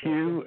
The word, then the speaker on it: Hugh: my uh, name is Hugh H U G H.